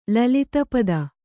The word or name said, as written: ಲಲಿತಪದ